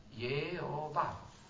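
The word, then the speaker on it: Jehovah: Ma se tu prendi quelle consonanti da un altro verbo che significa potere, allora le consonanti diventano altre, la E prima, la O in mezzo, la A finale, quella stessa parola come si legge? Yehovah.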